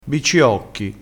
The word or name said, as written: bicciocchi